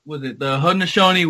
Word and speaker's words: Haudenosaunee: The Haudenosaunee? – So, The Haudenosaunee Wooden Stick Festival.